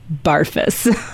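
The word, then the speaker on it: Barfuss: Barfus.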